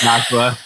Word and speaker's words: Joshua: You know, she'll read it like Josh Schwa, but it's just Joshua. you don't got you don't got to capitalize the U and the A. Just you put the wrong emphasis on the wrong syllable. – Yeah, Josh Cole. No, just other way, just Joshua. They had Joshua Cole.